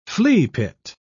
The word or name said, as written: fleapit